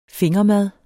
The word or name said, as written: fingermad